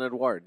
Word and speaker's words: Edouard: You don't have to look far down the list, until you find Crystal Palace's Edouard. – Yeah. – If I'm saying that correctly. – Yeah, Hudson Edouard.